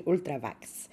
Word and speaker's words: Ultravox: I'm Dancing with Tear in My Eyes, τον Ultravox βγαίνει, Ultravax, να το πω και με την προφορά τρομάρα μας, βγαίνει το 84.